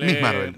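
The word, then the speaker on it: Ms. Marvel: Bueno, Mrs. Marvel. – Miss Marvel.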